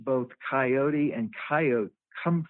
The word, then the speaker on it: coyotl: And the word was spelled C-O-Y-O-T-L, but the L in the Nahuatl language is a silent L. And so the name would have been pronounced coyote.